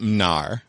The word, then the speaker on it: Mnar: I pronounced it Mnar. – Mnar. – Mnar. Mnar. It's Mnar. Like, it's where Steve Mnuchin's family is from.